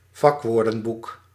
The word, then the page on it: vakwoordenboek: Dutch